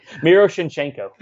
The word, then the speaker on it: Miroshnichenko: Uh, Ivan Miroshchenko. Oh, oh, I butchered that. Ivan Miroshchenko. I'm doing it again. This is a tricky name for me. – Miroshnichenko. – Thank you.